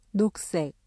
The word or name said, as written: 녹색